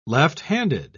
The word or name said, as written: left-handed